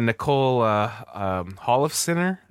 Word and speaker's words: Nicole Holofcener: Yeah, you're talking about Nicole Holofcener, I think is her name, how I say her name? – Yes, yes.